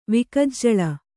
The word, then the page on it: ವಿಕಜ್ಜಲ: vikajjala